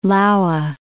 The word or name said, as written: Laue